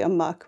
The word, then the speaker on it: Amok: It's Spock Amok, because Amok, A-M-O-K, is usually Amok, right? It feels like it should be pronounced Spock Amok, but it isn't.